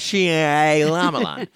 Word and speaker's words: Shyamalan: How do you say that name? – M. Night Shyamalan.